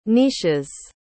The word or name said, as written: niches